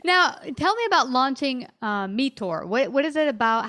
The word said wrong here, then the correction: Meeteor: So Meteor is the business that I said it wrong. – No worries. So Meteor, we are a little play on words because it's M-E-E-T-E-O-R.